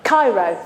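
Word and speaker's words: chaire: In the Nativity, again, Mary sees the angel and he says in Greek, Zairo. Have I said that right? Is it Zairo? Cairo. Thank you, my Greek specialist over there.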